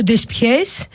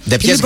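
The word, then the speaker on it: deux-pièces: Ντεσπιές είναι ντεσ. Ποιες, δύο κομμάτια. – Όχι δες. – Ντε ποιες γράφεται, αλλά πώς διαβάζεται. – Ναι, ντε.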